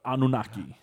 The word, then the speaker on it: Anunnaki: The Anunnaki. – Anunnaki. – Yeah, the Anunnaki. – Anunnaki. – Anunnaki. – Yeah, you guys, yeah, so it's spelled A-N-U, Anunnaki. Double N. So it's A-N-U-N-N-A-K-I. Anunnaki. Right?